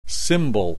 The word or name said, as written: symbol